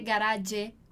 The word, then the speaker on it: garage: Garage é uma palavra no italiano que vem do francês, por isso que a gente não vai dizer garage com a pronúncia italiana, como a gente leria no italiano, mas é uma palavra francesa, então vamos dar uma afrancesada aí na palavra, ok?